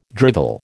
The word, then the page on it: drivel: American English